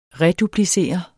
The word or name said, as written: REDUPLICER